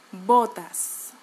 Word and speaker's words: botas: So, if you want to pronounce botas without an accent, you have to avoid avoid um, pronouncing the English O. Okay, O versus O, O, O, botas.